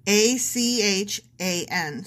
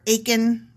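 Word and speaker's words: Achan: It's A-C-H-A-N. So it's like A-chan, but that sounds too close to 8-chan. Akin. Or Aiken, rhymes with bacon.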